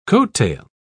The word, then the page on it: coattail: British English